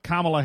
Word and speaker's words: Kamala: I guess you're probably thrilled about Kamala Harris. Is it Kamala or Kamala? I don't know. I say Kamala because that's what the vice president said. You say it like the punctuation mark, Kamala.